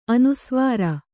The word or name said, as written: ಅನುಸ್ವಾರ